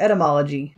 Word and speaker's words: etymology: Is that word pronounced etymology or etymology? It's English. – It's however you say it. – The common pronunciation tends to be etymology, like you would consider standard to be etymology. – Unless you want to eat it, apparently.